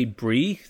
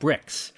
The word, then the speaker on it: Brix: And I would have assumed that it was pronounced J. Brie. It's spelled B-R-I-X. But I looked it up online just to be sure. And apparently they do pronounce it Bricks.